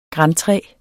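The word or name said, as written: grantræer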